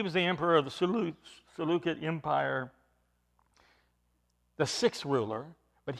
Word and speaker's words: Seleucid: I know some have heard, and you will hear it said Seleucid. The Greek is Seleucid. The C is pronounced like a K.